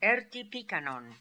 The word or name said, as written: Erti Pikan On